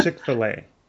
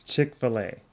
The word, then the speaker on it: Chick-fil-A: We don't have those in the Bay Area, so I don't even know how you pronounce it. – It's Chick-fil-A. – Is that what it is? – Chick-fil-A.